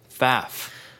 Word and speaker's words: Pfaff: Pfaff. – Pfaff is spelled P-F-A-F-F and is objectively a very, very funny name.